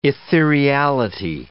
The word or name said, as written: ethereality